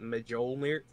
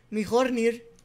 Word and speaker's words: Mjolnir: and I pronounced it Mjolnir. Mjolnir. I actually pronounced the J, which is very embarrassing.